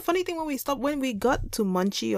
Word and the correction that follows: Muncie: or Muncie, Indiana, if anybody's out there and knows these places, we're sorry that we pronounced mispronouncing it.